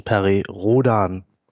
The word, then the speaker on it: Rhodan: Also die phonetische Aussprache, die offizielle ist, obwohl Perry Rhodan ein amerikanischer Staatsbürger gewesen ist, da es eine deutsche Weltraumserie allerdings ist, nennen wir den Menschen Perry Rhodan.